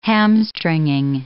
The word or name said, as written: hamstringing